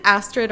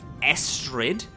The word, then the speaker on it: Estrid: I was gonna go up to like the window or something and just yell into, is it Astrid or Esther? Because I think we've said both. – So it's Estrid. It's spelled Astrid, but Estrid. With an E instead of an A.